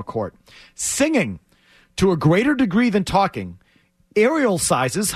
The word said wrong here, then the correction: aerosolizes: how would I pronounce that word, Maz? – Aerosolizes. – Aerosolizes.